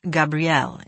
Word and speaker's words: Gabriel: Gabriel is a French name. It's pronounced Gabriel in French.